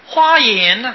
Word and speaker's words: 华严: 华严呢，这个华呢应当念成花啊，开花了啊，开花结果。